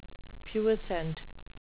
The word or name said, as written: puissant